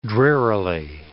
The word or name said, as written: drearily